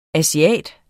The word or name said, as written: ASIATERNE